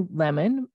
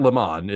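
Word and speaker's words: Lemmon: So it's not Lemon, it's Lemon.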